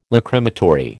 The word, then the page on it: Lachrymatory: American English